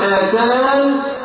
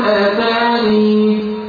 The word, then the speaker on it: آتان: كلمة آتان قلنا أصل ينطق فيها بطريقتين إما أن يكون آتان أو يكون آتاني.